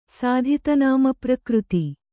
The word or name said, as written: ಸಾಧಿತನಾಮಪ್ರಕೃತಿ